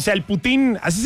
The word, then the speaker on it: poutine: Se escribe poutine. – Ahí está el poutine, uh-huh. Poutine, pero se pronuncia putín.